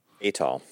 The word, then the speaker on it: atoll: It's in a, you'll say it right, atoll? – Atoll. – Atoll. – It's an atoll.